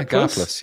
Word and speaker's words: Gaplus: I don't know how you pronounce it. Is it Gaplus? Gaplus? – Gaplus? Yeah, Gaplus. Yeah, Gaplus.